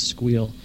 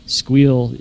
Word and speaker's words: Sguil: The purpose of my talk there was to discuss a project, an open source project that I've been involved with called Squeal. Squeal is spelled S-G-U-I-L.